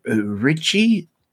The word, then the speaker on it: Ricci: We've also got Christina Ricci.